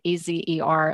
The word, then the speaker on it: Ezer: And as part of that, part of my interest in that was this word, Ezer, Azer, Azer is sometimes called as or pronounced as well.